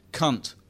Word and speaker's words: Kant: We stared at one another wildly whenever he said, with emphasis, cunt, which, he assured us, was the correct English pronunciation for the German philosopher Immanuel Kant.